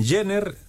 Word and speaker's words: Jenner: Recuerden que Jenner se escribe con J, J-E-N-N-E-R. Es con doble N.